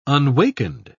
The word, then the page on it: unwakened: British English